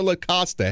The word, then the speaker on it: La Costa: Am I saying this right? La Costa or La Costa? How are you? – It's La Costa.